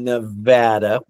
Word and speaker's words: Nevada: We live in Sparks and we teach in Sparks, but nobody knows exactly where that is unless you're from Nevada, which is the correct way to say it.